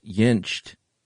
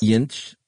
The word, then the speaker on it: Jentsch: I did not realize that's how his last name should be pronounced. – Jentsch. – That's good stuff. – I think I put a T on the end, but the T's in the middle. Jentsch.